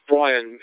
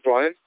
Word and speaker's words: Brian: My name's Brian. It's Bravo, Romeo, Yankee, Alpha, November. I spell it with a Y, Brian.